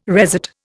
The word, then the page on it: resat: Indian